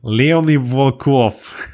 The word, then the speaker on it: Leonid Volkov: Leonid Volkov.